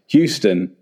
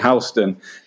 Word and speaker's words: Houston: And you mentioned Houston Street there, which in your really, really helpful notes that you sent is spelt in such a way that you would imagine it was pronounced Houston, as in Whitney Houston or Houston, Texas. But you very kindly showed us phonetically that we should pronounce it Houston, so that if we're asking for directions, we wouldn't get a sarcastic answer from any New Yorkers.